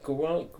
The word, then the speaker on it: Guillaume: But they've also got, I kind of pronounced this guy's name properly, Robert Guillaume.